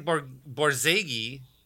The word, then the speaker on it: Borzage: And Frank Borzage, I've pronounced his name every possible way. – That's the right way. I'm pretty sure that's right.